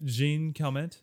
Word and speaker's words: Jean Calment: Jean Calment.